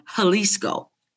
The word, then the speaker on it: Jalisco: And I moved to the state of Jalisco, spelt with a J, pronounced with an H, you know, the whole Spanish thing.